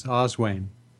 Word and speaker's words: Oswain: My name is Oswain. – Oswain? – Yes. – Could you spell that in English for us? – O-S-W-A-I-N. – Okay, just like it sounds.